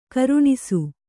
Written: ಖುರಣಸ

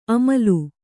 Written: ಅಮಲು

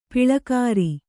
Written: ಪಾಳ್ಳೆಗಾರ

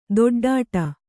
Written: ಥಟ್ಟೊಡ್ಡು

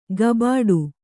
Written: ಗಬಾಡು

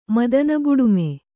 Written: ಮದನಬುಡುಮೆ

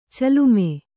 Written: ಚಲುಮೆ

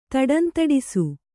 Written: ದಡಂದಡಿಸು

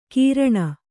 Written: ಕೀರ್ಣ

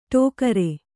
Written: ಠೋಕರ

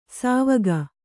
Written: ಸವಗ

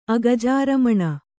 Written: ಅಗಜಾರಮಣ

